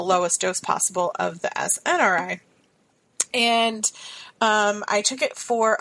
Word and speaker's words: SNRI: I decided that I wanted to instead switch to an SSNI. Am I saying that right, Sarah? – SNRI? – Yes, and SNRI.